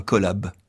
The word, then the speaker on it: collab: On dit, vous avez entendu, un collab, c'est-à-dire deux syllabes, seulement deux, et la consonne finale.